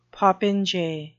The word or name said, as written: popinjay